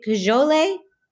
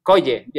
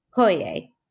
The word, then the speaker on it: Coye: I know you're working with her at Central, and she has, do you pronounce it? Is it Cajole? – Coye, yeah. – Coye. – Yeah, it's even difficult to mention in Spanish, so no worries about Yeah, I'm sorry. I try my best on pronunciation, but it's not, I apologize in advance. – No worries, because it's a Quechua word, so it's not even Spanish. So it's even difficult for people from Lima, from South America, to pronounce Coye.